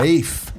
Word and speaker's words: Ralph: It's spelled Ralph, but it's pronounced Rafe. – Really? Yeah. Rafe.